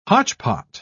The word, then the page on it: hotchpot: British English